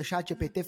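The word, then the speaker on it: ChatGPT: chat GPT, né? É isso? É chat GPT, falei certo? Falou, Perdão, falou porque certíssimo.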